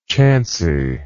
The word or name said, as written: chanciness